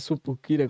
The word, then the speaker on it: sucupira: Mas a supi, é, sucupira, como é que fala? – Sucupira. É, rapaz, sucupira mesmo, viu?